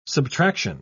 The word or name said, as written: subtraction